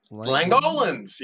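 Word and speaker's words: Langoliers: Yeah, or the I think I'm pronouncing this right, the Langolans? – Langolans, yeah. – Yes.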